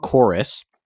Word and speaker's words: Chorus: It's called Chorus. It's that like space sim, like it's an arcade like shooter. The spelling is actually with a V, but it's pronounced chorus, and it's pronounced chorus.